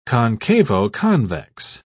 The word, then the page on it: concavo-convex: British English